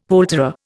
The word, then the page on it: poulterer: Indian